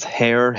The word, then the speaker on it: Hehir: I'm going to pronounce his name as Hair, H-E-H-I-R.